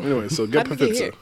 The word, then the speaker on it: Pfizer: Pfizer. – P is silent, brother.